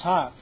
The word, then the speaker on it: houghed: It's pronounced Hocked. H-O-U-G-H-E-D. Okay, see that? They put a C-H in there. If you look it up in Webster's Dictionary, this word is pronounced hock, H-O-K.